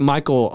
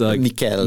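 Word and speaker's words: Michiel: Ed Skrein, Anthony Hopkins, Mikael Huisman. It looks like Michael Huisman, but it's Mikael.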